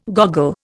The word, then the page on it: goggle: Indian